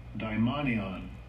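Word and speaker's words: daimonion (δαιμόνιον): Strong's G 1140, daimonion. Daimonion, which is demon. That's what devils is. It's a demon. That's how you say it in the Greek.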